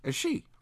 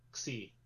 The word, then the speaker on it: Xi: You know, as we know, the last one was new, and as we know by Greek lettering, the next one is she. – Oh no, it's pronounced Xi.